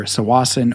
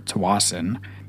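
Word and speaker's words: Tsawwassen: Depending on where you are in the province, you pronounce it either Tawasin or Tawasin.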